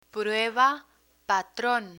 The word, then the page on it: prueba patron: Spanish